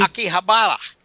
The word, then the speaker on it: Akihabara: Akihabara.